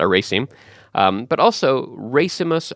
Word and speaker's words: racemus: It comes from the Latin word racemus or racemus, racemus. I'm not really good at pronouncing Latin, but it's R-A-C-E-M-U-S.